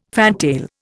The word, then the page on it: fantail: Indian